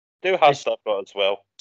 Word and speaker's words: Du Hast: No, I went with, is it Du Hast? Is that how you say it? – Yes, Du Hast.